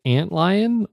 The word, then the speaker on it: Antlion: Do they pronounce it Antlion or something else? – Yeah, that is how they pronounce it.